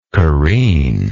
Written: careener

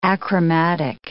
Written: achromatic

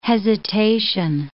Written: hesitation

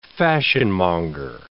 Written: fashionmonger

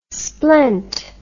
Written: splent

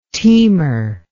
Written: teamer

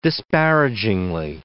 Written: disparagingly

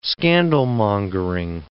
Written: scandalmongering

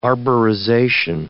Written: arborization

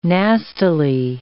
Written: nastily

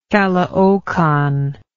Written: caloocan

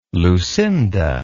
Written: Lucinda

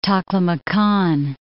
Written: Takla Makan

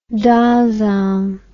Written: Dasein

